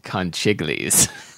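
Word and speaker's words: conchiglie: I believe it's pronounced conchiglis. – Conchiglis, yes.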